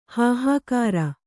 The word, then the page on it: ಹೂಹಗುರ: kannada